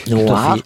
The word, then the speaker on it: Nwar: En seconde partie, le groupe Noir. Ai-je bien prononcé? – Oui, Noir. Mais alors, j'épelle parce que c'est pas noir comme la couleur. Oui. Ça s'écrit N-W-A-R.